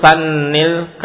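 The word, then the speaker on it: sanni (ثنِّ): Sampai kita masuk ke latihan yang ke-6, yaitu sannil kalimat allati tahtaha khat. Sanni, nah itu kalau di cara kana bacanya sanna tapi salah, ya benar sanni, harakat kasrah.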